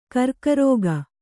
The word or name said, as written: ಗುರಿಗಾರಿಕೆ